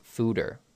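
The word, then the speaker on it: Fuder: Oh, it's pronounced fooder.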